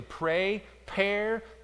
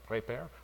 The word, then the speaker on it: Praypare: By the way, number 2, just parenthetically, we used to say pre-prayer, but that was too hard to say, so now we say pray, pair, P-R-A-Y-P-A-R-E. It's a little easier to say. It's best if you pronounce it with a little bit of a French, like prépare, like that.